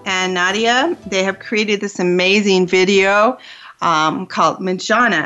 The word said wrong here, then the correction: Menjangan: So, am I saying that right, Manjana? – It's called Manjangan. M-E-N-J-A-N-G-A-N.